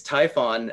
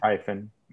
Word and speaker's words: Typhon: Like, it's Typhon, and yes, that's how you say his name, the deity? – Typhon.